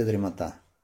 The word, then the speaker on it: дремота: Но в любом случае, дремота или дремота? Дремота почему? Говорят по принципу зевота. Зевота, дремота, ударение на этом слоге, на втором слоге.